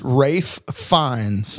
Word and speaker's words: Ralph Fiennes: Yes, and it's Ralph Fiennes.